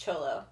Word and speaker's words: Tholo: It's spelled Tholo. It's pronounced Cholo. – Okay. – I always feel awkward saying that, but that's how you say it.